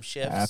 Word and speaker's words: Schiff: Adam Schiff.